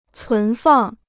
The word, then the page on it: 存放: cún fàng